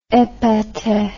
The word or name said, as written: epater